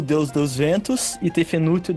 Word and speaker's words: Tefnut: Não, mano, a pronúncia egípcia é foda mesmo. Tefnut. – Sendo... Não, é que... É ruim de falar mesmo. É Tefnut. É zoado.